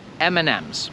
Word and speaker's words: M&M's: Akurat tutaj nie jestem pewny, jak wy to wymawiacie, ale tutaj to będzie M&M's. Bo tutaj przez to, że mamy M i potem drugie M, to my nie mówimy M and M, mówimy to jako N, M&M.